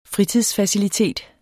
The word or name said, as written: fritidsfacilitet